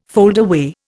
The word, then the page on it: foldaway: Indian